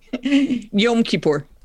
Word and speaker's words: Kippur: But like, and forgive me for mispronouncing, I'm dangerously illiterate, but for Yom Kippur. – Close enough. – Yeah. Is it Kippur? – Yom Kippur.